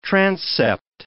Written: transept

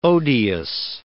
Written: odious

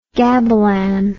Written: gabilan

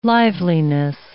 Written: liveliness

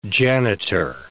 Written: janitor

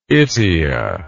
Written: itea

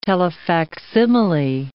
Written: telefacsimile

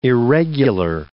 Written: irregular

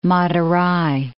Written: Madurai

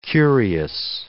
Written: curious

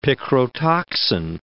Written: picrotoxin

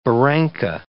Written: barranca

